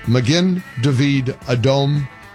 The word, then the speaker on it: Magen David Adom: And thank you for correcting my pronunciation, Magen David Adom, Israel's National Emergency Medical and Blood Services Organization.